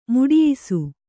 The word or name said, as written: ಮಡಿಯಿಸು